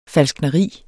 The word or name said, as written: falskneri